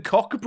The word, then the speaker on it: Koch: Yeah, but you don't pronounce it that, do you? – Yes, you do. Koch. – You don't. – Koch. Like a Liverpudlian would say, "Give me your Koch."